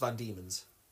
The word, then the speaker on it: Van Diemen's: it's not spelt V-A-N... space D-E-M-O-N. It's spelled Van, and Demon is D-I-E-M-E-N, I believe. So Van Diemen's, almost. – Van Diemen's Land. Van Diemen's Land. – Yeah, yeah, how that's... Yeah, but it's pronounced Van Diemen's.